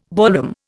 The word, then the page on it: ballroom: Indian